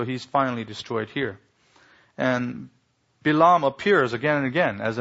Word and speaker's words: Balaam: Now, I keep pronouncing it Bilam because that's how you pronounce it in Hebrew. We get the English Baalam from some mistransliteration. But anyway, it's not a poor pronunciation. That's the Hebrew pronunciation, Bilam.